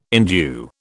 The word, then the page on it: endue: British English